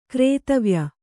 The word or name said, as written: ಘ್ರಾತವ್ಯ